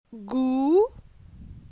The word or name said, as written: Gou